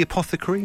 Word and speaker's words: apothecary: So I think I'm saying it right. Is it the apothecary? – Yeah.